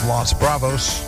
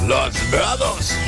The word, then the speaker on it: Los Bravos: Their name is Los Bravos, but in their native Spain, they're known as Los Bravos. They'd say it differently for some reason.